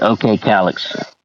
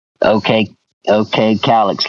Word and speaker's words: Okcalyxx: I pronounce it OK Calyx. OK Calyx. Like some people thought it was Oklahoma City Alex when I first started. They thought I was from Oklahoma City. My name was Alex.